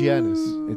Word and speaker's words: Giannis: Yanni is going to win MVP over Harden. – Why do you mispronounce his name? It's Giannis.